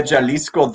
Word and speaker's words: Jalisco: How do you pronounce it? I just call him De La Jasco. – El Orgullo de Jalisco Dos, Chaparrito. – Oh, wow. Okay, much better than me.